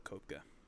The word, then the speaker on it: Koepka: I think it's Koepka. – Is it Koepka? – Yeah. – But it's spelled Koepka. – Yes, but it's Koepka.